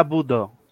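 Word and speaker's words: Bodø: Vale, se pronuncia Budo. Budo. Oh, ah, me gusta, vale, pues Budo.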